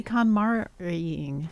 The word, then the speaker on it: KonMari: And happy KonMari-ing.